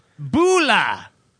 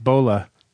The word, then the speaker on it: Bula: Bula, and if you happen to be from the Fiji Islands, please correct me if I'm pronouncing that wrong. Bula is Fijian for hello. Bula. You learn lots of shit on Stuck in the 80s, don't you? – I'm pretty sure Kevin Wunsch says that's pronounced Bola.